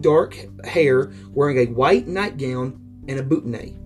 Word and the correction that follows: bonnet: I want to say that's how it's pronounced, or a bonnet. However you want to say it. – Bonnet, okay.